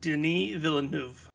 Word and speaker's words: Denis Villeneuve: It is pronounced Denis Villeneuve.